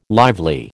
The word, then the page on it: lively: American English